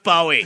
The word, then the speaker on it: Bowie: I was mispronouncing David Bowie a lot. David Bowie, of course, is like my favorite artist. Mm-hmm. And last week I was going, Bowie, Bowie, Bowie. And everyone Bowie. Like, it's Bowie. It's pronounced Bowie.